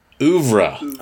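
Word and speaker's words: œuvre: Ouvre. – Oh, ouvre. – According to the Pronunciation Academy on YouTube, it's ouvre.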